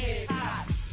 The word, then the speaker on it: hip-hop: Las H's no son mudas, se pronuncia hip-hop. Las H's no son mudas, se pronuncia hip-hop.